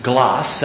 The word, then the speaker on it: Glas: So in the year 1773, a Scottish minister by the name of John Glass, that's how they would have pronounced it, G-L-A-S, died.